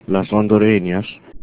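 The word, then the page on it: hondureñas: Spanish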